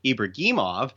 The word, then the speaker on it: Ibragimov: Timur Ibragimov, I know he'll eventually become Ibragimov, but for now I will call him the correct Russian pronunciation of Ibragimov.